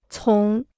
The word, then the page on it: 从: cóng